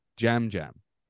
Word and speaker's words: Yam Yam: Maybe Yam Yam. He says it with, like, a soft J. Jam Jam.